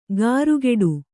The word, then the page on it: ಗಾರುಗೆಡು: gārugeḍu